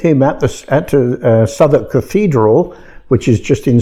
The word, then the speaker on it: Southwark: Yes, we pronounce it Southwark, but that's the English pronunciation.